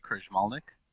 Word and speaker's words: Krajmalnik: I am Enrique Krajmalnik.